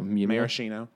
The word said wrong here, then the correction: Moschino: Moschino. Moschino.